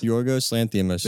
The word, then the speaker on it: Yorgos Lanthimos: I don't want to say his name wrong, but the director of The Killing of Sacred Deer, Yorgos Lanthimos. – Yorgos Lanthimos.